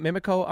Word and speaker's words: Mimico: So you grew up in Mimico. Am I saying it right? Mimico, Ontario? – Yep. You got it right.